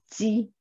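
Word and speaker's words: ji (鸡): this happens to mean chicken in mandarin we pronounce it ji so there's a way to transliterate that sound, and it looks like that.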